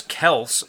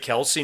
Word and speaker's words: Kelce: Yeah, so so Travis Travis Kels apparently he said that uh, people people have been saying his name wrong, but you know when he first got to Kansas City, he just eh, people said it Kelsey, and he just stuck with it, which is really weird because his brother his brother's been in the league too, and they, you know, we've called him Kelsey.